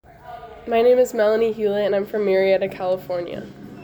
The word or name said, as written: Melanie Hewlett